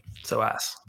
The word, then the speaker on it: psoas: It's PSOAS. It's like the muscle, like near your hip flexor. – PSOAS. – Are you guys familiar with that? PSOAS, is that how you say it? – Yep.